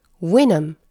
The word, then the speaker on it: vinum: 이는 라틴어로 와인을 뜻하는 vinum, v-i-n-u-m 에서 유래하였기 때문이죠. 그런데 사실 라틴어의 v 사운드는 초기 라틴어의 w 사운드가 후기 라틴어의 V 사운드로 변화한 것이 많습니다. 즉, 초기 라틴어에서 포도주는 winum, winum으로 W 사운드로 시작하며 발음하였죠.